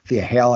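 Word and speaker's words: Thea Hail: Then we get Chase Yu chatting and Duke Hudson stops mid-story to go check on Thea Hall. Thea Hale, I always pronounce that wrong.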